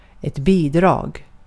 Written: bidrag

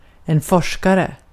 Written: forskare

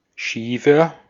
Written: schiefer

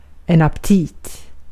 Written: aptit

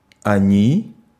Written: zij

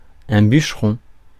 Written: bûcheron

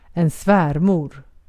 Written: svärmor

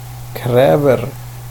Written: kräver

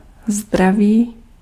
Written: hälsa

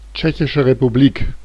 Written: Czech Republic

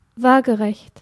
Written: horizontal

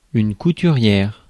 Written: sewer

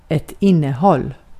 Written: innehåll

